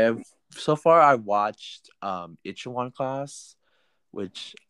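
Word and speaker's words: Itaewon: It's called Ichiwan Class.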